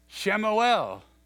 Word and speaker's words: Shemuel: Shemuel, that's the way you pronounce Samuel's name.